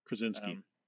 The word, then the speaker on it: Krasinski: Krasinski. – John Krasinski.